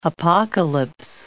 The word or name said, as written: apocalypse